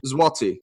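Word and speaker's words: złoty: Zloty. So it's Z with a, it looks like an L, but it's actually pronounced as a W.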